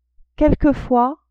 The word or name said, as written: quelquefois